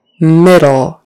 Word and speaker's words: middle: Middle. It is in the middle of the road. Don't run over it. Middle, with a short I. Middle. And that D is a D, not a T.